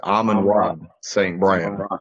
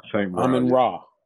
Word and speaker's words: Amon-Ra St. Brown: Because y'all been butchering this man's name the whole podcast. It's Amon-Ra St. Brown. – Amon-Ra.